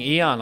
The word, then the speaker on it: Eoin: Eoin Colfer, the name looks like E-O-I-N, yeah. which fooled many of us. We had no idea quite how to pronounce it. – I'll take anything. Eon, I quite like. It's very spacey.